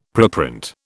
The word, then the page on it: preprint: British English